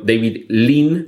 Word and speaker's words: David Lean: Eh, me he referido a Breve Encuentro, que es una película de David Lean, mm, pronunciado David Lean, pero como se puede confundir con David Lynch,